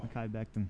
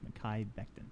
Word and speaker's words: Mekhi Becton: The my boy Beckon, though. – Mekhi. – Oh. – Mekhi Becton. – Oh. – Beast. – I'm obsessed with this dude, and I still can't get his name right, and I'm going to butcher it forever, probably, until I buy his jersey. – Pretty sure it's Mekhi Becton.